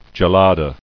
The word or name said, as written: Gelada